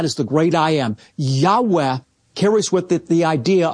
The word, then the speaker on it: Yahweh: It's known as the Tetragrammaton, and most Semitic scholars believe it's pronounced a breathy Yahweh.